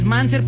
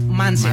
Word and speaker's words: MNZR: Es Manser, pero se escribe M-N-Z-R. – Ok. – M-N-Z-R Manser. – Manser.